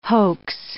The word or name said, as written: hoax